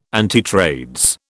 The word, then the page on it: antitrades: British English